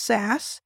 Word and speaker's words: SaaS: it's pronounced sas and i used to say that i was sas s-a-s-s as a service